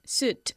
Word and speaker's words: suit: ¿Y cómo se pronuncia? Suit. – Suit. – Suit. Oot, ok. Like boot. – Suit. – Suit. Exactamente igual que bota. Boot y suit.